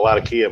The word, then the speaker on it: Latakia: Latakia versus Latika. Same fucking thing. – I call it Latakia because that's how the town's pronounced in Turkey. Or Syria, I mean.